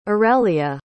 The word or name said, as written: aurelia